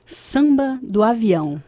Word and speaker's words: Samba do Avião: So we started off our set with Samba de Avião. – Ooh, that was good. They'll be almost there. – How would you say it? – Samba do Avião. – Samba de Avião. – Yes, that's good.